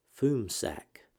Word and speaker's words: FMSAC: It is a memo from October 1968 reporting on the activities of the CIA's Foreign Missile and Space Analysis Center, which is abbreviated FMSAC and pronounced FUMSAC.